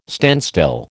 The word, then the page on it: standstill: American English